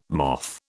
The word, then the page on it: moth: British English